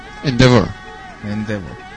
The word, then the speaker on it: Endeavour: Endeavour.